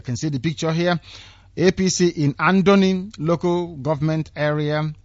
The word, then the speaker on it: APC: Another APC or AFC as the Hausa person will pronounce it. You know, they don't have P. It's F, AFC. APC office bombed, 5 youths die in Abua, Odua.